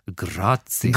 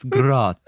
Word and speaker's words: Grazia: I think it's pronounced Grazia. – Grazia. – Yes, Grazia.